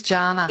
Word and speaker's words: Jana: And is it, I don't know if I've pronounced your name right, is it Jana or Yana? – It is Jana. – It is Jana. Oh, yes, I did grow up with Jana Vent on TV, but mine's a hard J, so thanks for checking.